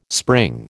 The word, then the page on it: spring: American English